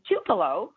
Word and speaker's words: Tupelo: But everyone, Kristen, which is Kristin Herr, was born and raised in Tupelo. I think I said that right.